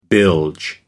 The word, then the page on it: bilge: American English